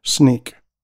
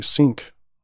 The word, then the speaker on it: Snyk: And Snink, which is a very strange name for a company, it's spelled S-N-Y-K, Sneak, which I keep wanting to say Sync.